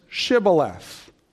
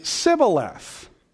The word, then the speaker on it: Shibboleth: And the reason why they picked that word is because Ephraimites couldn't pronounce the sh sound of the word. So they would say Sibboleth.